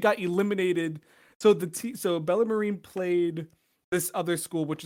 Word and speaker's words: Bellarmine: Bellarmine.